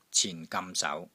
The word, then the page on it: CIN4 GAM6 SAU2: Cantonese